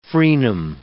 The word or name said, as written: frenum